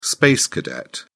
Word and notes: space cadet: British English

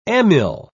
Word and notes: amyl: British English

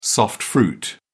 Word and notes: soft fruit: British English

